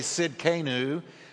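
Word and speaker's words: Tsidkenu: Now, the next one is pronounced Sidkenu, or really actually Sidkenu.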